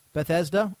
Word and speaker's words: Bethesda: Um, Game and Game Station struck a deal with uh, Beth, Beth can never pronounce that correctly. Bethesda and THQ to block those games from Steam for UK users only.